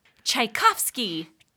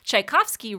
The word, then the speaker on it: Tchaikovsky: Tchaikovsky. – Tchaikovsky. – Good job. This piece Tchaikovsky wrote for the piano.